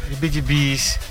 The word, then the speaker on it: heebie-jeebies: Is H-E-E-B-I-E. – Hibijibi. – Yes, Hibijibis.